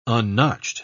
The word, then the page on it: unnotched: British English